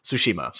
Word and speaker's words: Tsushima: I can't remember exactly how to pronounce it, but Tsushima.